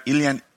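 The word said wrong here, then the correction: Ilyin: Ilian? Mm-hmm. Is that right? – Ilin. – Yeah. right.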